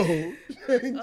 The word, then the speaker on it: Shang Tsung: Shang Tsung.